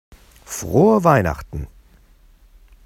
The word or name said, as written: frohe weihnachten